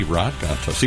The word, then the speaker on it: Gontos: It should be Rod Gontos, even though it's spelled Rad.